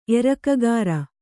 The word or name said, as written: ಎರಕಗಾರ